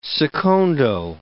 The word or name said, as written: secondo